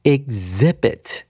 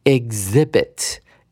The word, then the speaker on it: exhibit: 一起来看一下它的拼法，e x h i b i t，念成 exhibit。首先是 e x 的念法，它会把那个 x 的音呢拆解成为 g z，就是 g 跟 z 的音，所以 e x 会念成一个 z。然后重音在第二音节的 i 上面，h 不发声，所以它要念成 exhibit。